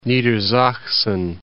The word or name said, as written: Niedersachsen